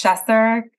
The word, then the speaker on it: Schesser: It's Schesser. I could have been a professor.